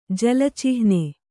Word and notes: ಜಲಚಿಹ್ನೆ: jala cihne

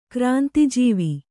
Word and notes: ಕ್ರಾಂತಿಜೀವಿ: Kannada

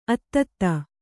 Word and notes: ಅತ್ತತ್ತ: attatta

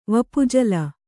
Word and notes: ವಪುಜಲ: Kannada

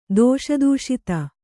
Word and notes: ದೋಷದೂಷಿತೆ: dōṣa dūṣite

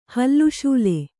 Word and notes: ಹಲುಸಲ್ಲೆ: halu salle

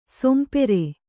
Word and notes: ಸೊಂಪೇರು: sompēru

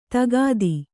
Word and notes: ದಗದ: dagada

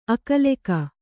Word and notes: ಅಕಲೆಕ: akaleka

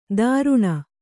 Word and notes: ತರುಣ: kannada

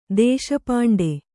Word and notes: ದೇಶಪಾಂಡೆ: dēśa pāṇḍe

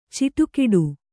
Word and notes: ಚಿಟುಕಿಡು: ciṭukiḍu

